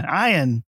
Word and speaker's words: Ian: Ian. – Ian. – Ian McShane, spelled with an I, which would be Ian. Not E-A-N, which would be Ian.